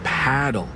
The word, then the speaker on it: padel: No, that's paddle. Rhymes with saddle.